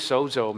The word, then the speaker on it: sozo: So somebody can look at Pastor Dan and say, oh, that brother, he's sozo, man. He's sozo. Woo! S-O-Z-O is how you spell it. But in Greek, the Z is pronounced like it's a D, D-Z. Sozo.